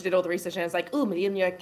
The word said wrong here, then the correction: Mjolnir: can, I pronounced that so wrong, can cure me, and like Mjolnir.